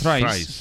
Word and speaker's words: Thrice: Eh, su último disco se titula Winter Thrice. – Thrice. Thrice, exacto.